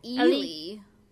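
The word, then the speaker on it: Eli: So, listening, I was trying to get the way they pronounced Ellie. Would you say that they are saying Ellie? – They said Ely. – Ely? – They were saying Ely in the movie.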